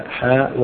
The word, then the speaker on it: حاء: المغاربة يقولون هذه الحاء معناها الحديث، اختصار لكلمة الحديث، والأكثر على أنها حاء التحويل، وتقرأ مفردة هكذا حاء ويمر، يعني بسرعة حاء وحدثنا.